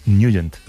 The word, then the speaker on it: Nugent: Hombre, siempre lo hemos llamado en el barrio Ted Nugent o Ted Nugent. – Claro, pero, yo era de Ted Nugent. – Pero la pronunciación correcta es Nugent.